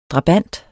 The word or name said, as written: drabant